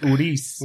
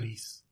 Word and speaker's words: Ruiz: It's Ruiz. – Ruiz, right? – Okay. Yeah, Ruiz. Just got to roll the R, okay. Man. – Yep, exactly, dude. Ruiz.